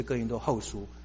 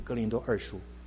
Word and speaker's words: 哥林多后书: 如果他常常去做礼拜，他应该也会听到人家讲是哥林多后书，而不是哥林多二书。